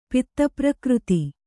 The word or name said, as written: ಪಿತ್ತಪ್ರಕೃತಿ